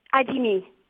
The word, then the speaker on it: Adimi: Je voudrais que vous nous rappeliez, parce que je ne l'ai pas bien compris, on parle bien de Kouter Adimi, c'est ça, ou Adim? – Adimi. – Alors, ça s'écrit A-D-I-M-I? – I.